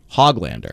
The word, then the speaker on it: Höglander: Nils Höglander has told us he wants us to pronounce his name Nils Höglander.